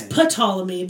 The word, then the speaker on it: Ptolemy: It's Ptolemy, but the P is silent because it sounds stupid to say Ptolemy.